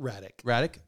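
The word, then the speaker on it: Hradek: Hardick. I don't know who they are, but they're for the NHL. What? – Radic. – Okay. – Oh, the H is silent.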